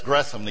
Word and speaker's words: Gresham: That's Gresham, the H is silent.